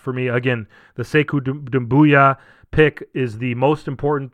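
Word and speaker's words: Doumbouya: Again, an A for me for the Siku Dumbuya, or Dumbuya. I mean, it's going to be hard for me to switch up the last name pronunciation, but Dumbuya.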